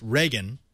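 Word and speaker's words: Regin: I've heard two pronunciations for this. Some are calling it Region. I've heard it pronounced Reagan. Which one do you use? – Well, it's R-E-G-I-N. So I think you can kind of go either way.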